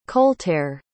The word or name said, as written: coltere